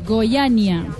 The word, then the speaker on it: Goiânia: a Goiânia o Goyanía. ¿Cómo se dice? – Goyania.